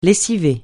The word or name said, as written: lessiver